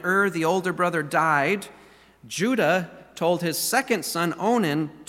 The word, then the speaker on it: Judah: Name starts with a J, rhymes with Buddha. Judah, that's right, Judah.